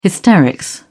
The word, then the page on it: hysterics: British English